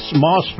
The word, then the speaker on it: Maus: Alrighty, and this portion of the program brought to you by Moss Family Automotive. Moss is spelled M-A-U-S, Moss Family Automotive.